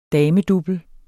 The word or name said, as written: damedouble